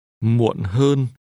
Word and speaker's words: muộn hơn: This is pronounced with a low falling tone. Muộn. And a mid-level tone. Hơn. Muộn hơn.